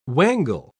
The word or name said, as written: wangle